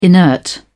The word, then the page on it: inert: British English